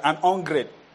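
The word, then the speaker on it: an hungred: I pronounce it as an ungred.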